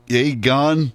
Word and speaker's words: Aegon: Aegon.